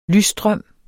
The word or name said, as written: lysstrøm